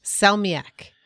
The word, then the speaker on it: salmiak: It's like it's called, and this is, it's actually written on one of these packets, salmiak. I don't know if I'm pronouncing that right. – Yes.